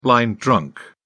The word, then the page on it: blind drunk: British English